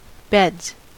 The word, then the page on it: beds: American English